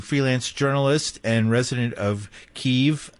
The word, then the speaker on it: Kyiv: We say Kyiv, because you have to put it like K-Y-I-V, because it's Ukrainian spelling of the name of Kyiv. And K-I-E-V right. – is Russian Soviet name of pronouncing it.